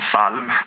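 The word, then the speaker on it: psalm: Men till exempel så har vi ord som salm till exempel, eller ljud, där vi då stavar LJ på ljud och salmen med P, men vi uttalar inte P, det är liksom stumt.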